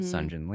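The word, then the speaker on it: Seongjin: Sunjin Lee.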